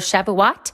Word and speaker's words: Shavuot: And I say it incorrectly on my conversation in the conversation with Lori, but I did some more research afterwards. And so it is the festival of Shavuot.